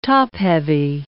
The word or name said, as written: top-heavy